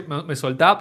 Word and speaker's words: Me Soltá: Bien, yo lo pronuncié, Me Soltá, pero ahora vos lo pronunciás bien en portuñol, Me Soltá Montevideo.